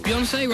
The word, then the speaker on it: Beyoncé: Beyoncé rhymes with fiancé, which is a term for someone you plan to marry, or as opposed to use house-buying terminology, is under offer.